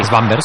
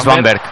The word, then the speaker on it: Svanberg: Svanberg, el... No sé si decís, se dice así. – Svanberg. – Sí, Svanberg.